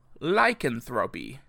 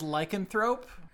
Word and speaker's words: lycanthropy: And then the second term I have to define for you is lycanthropy. The supernatural transformation of a person into a wolf. Lycanthropy. – Oh, is that how it's pronounced? – Uh-huh. – I always thought it was lycanthrope.